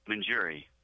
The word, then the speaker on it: Monjure: Monjure.